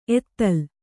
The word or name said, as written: ಎತ್ತಲ್